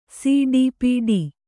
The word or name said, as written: ಸಡಪುಡ